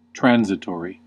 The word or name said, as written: transitory